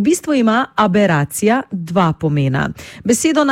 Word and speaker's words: aberacija: Gre za samostalnik ženskega spola, besedo sestavlja 9 črk, od tega 5 samoglasnikov in 4 soglasniki, pri izgovori pa smo pozorni na drugi A, torej nad njim napišemo ostrivec, pravopisno gledano, zato ga pri izgovorjavi tudi poudarimo. Pravilno izgovorjena beseda zveni tako: aberacija.